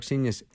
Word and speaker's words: Seignious: Mark Seignious is my guest, and his last name is spelled just the way it sounds. Yeah. Good luck with that. – It rhymes with genius.